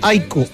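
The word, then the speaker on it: AIK: Oiga, un buen amigo que está en sintonía, Marcelo, que vive en Suecia, dijo: no tengan inconvenientes, no se compliquen, díganle Aiko al equipo, pronúncielo como el Aiko.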